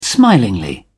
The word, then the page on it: smilingly: British English